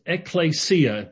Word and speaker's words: ekklesia: The Greek word for church is ekklesia, very important Greek word.